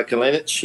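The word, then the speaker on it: Kalinić: Another favorite story from that game is their striker, Kalinic. Is that how you pronounce it? – Yeah, Nikolai Kalinic.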